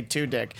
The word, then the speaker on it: Tudyk: I always thought it was something different, but I like to say Tudyk.